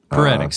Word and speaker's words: Peretic: Nathan Peretic. Peretic? Peretic? – Peretic sounds right. – Peretic sounds like heretic, and that could be right.